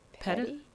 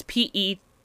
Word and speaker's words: Pettus: I think it's Pettus. It's Pettus. Oh, Pettus.